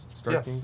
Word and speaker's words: Starkings: Starkings? – Yes.